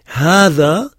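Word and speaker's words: Hada (هذا): Hada, on prononce ha, mais on écrit que le ha. Le elif est suscrit. Il existe, mais on ne l'écrit pas.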